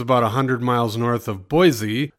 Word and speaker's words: Boise: Everyone says there's no Z in it, and they get irritated if you don't say Boise.